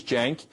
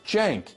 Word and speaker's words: Cenk: So the first name is Cenk. It's spelled C-E-N-K. But the C in Turkish is pronounced like a J, like Cenk, like short for Jenkins. Okay? Cenk. Second name, surname is Uygur. U-Y-G-U-R.